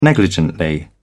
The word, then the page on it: negligently: British English